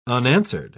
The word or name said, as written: unanswered